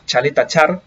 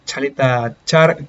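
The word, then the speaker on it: Ćaleta-Car: Chaleta Char, perdón por la pronunciación, igual antes le había dicho Caleta Car, pero una, una falta de comunicación entre Quasans y Chaleta Char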